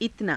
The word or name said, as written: itanaa